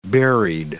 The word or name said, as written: berried